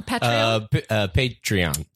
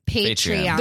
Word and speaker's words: Patreon: How do you say Patron? – Patreon. – Patreon.